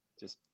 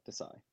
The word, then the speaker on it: Desai: Desai. Just like that. Desai.